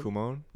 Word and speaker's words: Kumon: Kumon.